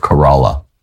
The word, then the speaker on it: Corolla: Oh, is that how you pronounce it? – It is. – Was I always pronouncing it wrong when we were there? – Well, we both thought it was Corolla, like the car, but we were wrong.